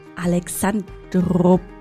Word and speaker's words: Alexandroupolis: Genauer gesagt in Alexandropolis.